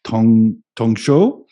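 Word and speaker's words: tong shou (唐手): No, it was used in a Chinese character, and in Chinese, in Mandarin, it's pronounced tong shou, and it means China hand.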